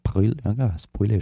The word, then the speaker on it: Brûlé: Brûlé oder Brûlé spricht man den aus.